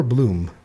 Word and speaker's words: Blum: Is it Blum or Bloom? Andrew Blum, we'll call it. I've been pronouncing it Bloom.